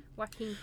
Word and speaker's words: Joaquin: Is that how you say his name? Wacken? – Joaquin.